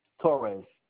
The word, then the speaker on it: Torres: Torres, T-O-R-R-E-S.